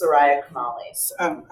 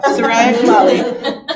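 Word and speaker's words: Chemaly: And you quote, is it Soraya Chemeli? Is that how you pronounce? – I think it's Soraya Chemeli. – I was way off. Soraya Chemeli, who writes, anger begets transformation, manifesting our passion and keeping us invested in the world.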